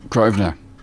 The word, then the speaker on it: Grosvenor: Detectives have been told the victim was forced into Portland Place between Grosvenor. Is it? – Yep, Grosvenor. – And Cliff Street, is that yes. How you pronounce that one? – Grosvenor and Cliff.